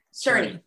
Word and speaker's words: Czerny: And you actually, Henry Czerny. – Czerny.